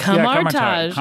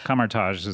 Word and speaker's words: Kamar-Taj: I just said Kamar-Taj, but I don't know if that's correct. – Kamar-Taj. Yeah, Kamar-Taj is how they pronounce it, yeah.